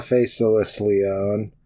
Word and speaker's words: Faceless Leon: I decided recently that there's an extra syllable in there. This is Green and Faceless on the couch. You know, just because, like, I want it to be more like a name than a title. – It's or more like a descriptor. Yeah, it's like a... – You know. – It's like the old-timey poetry where they pronounce the EDs at the end of the words. You know, it's like, dissolved. Yeah, you're faceless. Right. The E is pronounced.